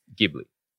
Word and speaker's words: Ghibli: It's pronounced Ghibli, a hard G. This is the GIF thing all over again. But when it is translated into Japanese and spelled out in katakana lettering, it's a little bit more like Ghibli.